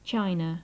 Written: china

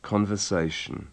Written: conversation

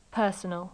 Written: personal

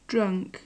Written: drunk

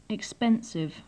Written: expensive

